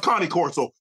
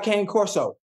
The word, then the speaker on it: Cane Corso: It's pronounced Connie Corso, but okay, go ahead, take off. – I like Kane Corso. – Yeah, they know what you like. I'm telling you what the pronunciation of the dog is.